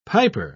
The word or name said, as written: piper